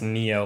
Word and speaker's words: NIO: N-I-O? – They told me that it's pronounced Neo, like N-E-O. – Oh, like Neo in the Matrix. – I was calling it N-I-O for a day.